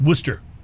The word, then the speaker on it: Worcester: There's a town a little outside of Boston that's spelled Worcester, but it's pronounced Worcester.